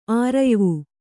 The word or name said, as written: ಆರಯ್ವು